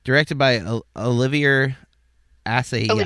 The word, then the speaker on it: Assayas: It's Assayas, I think is how you say his name. It's Assayas.